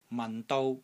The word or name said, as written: MAN6 DOU1